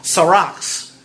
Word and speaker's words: sarx: Because the word that flesh is translated from is a word that you'll recognize, and it's the word in the Greek, S-A-R-X, sarax. You have to roll the R's, but I can't do that. And so that's what sarax is what it is, the way it's pronounced, with a rolled R.